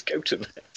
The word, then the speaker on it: Gotham: So I have to admit, I have absolutely no idea if you're just winding me up about the pronunciation of Gotham, but... – No, it's Gotham. It is.